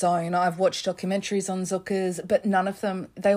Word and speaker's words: Cukurs: Now, yes, if you're looking at the description or the title of this show, which luckily you did, it looks like his name is pronounced Cukurs or Cukurs, his surname, but it's actually pronounced Cukurs, just to clarify that.